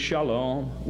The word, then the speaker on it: shalom: And the term peace, pronounced today by the Jews shalom, was the favorite thing among the Jews.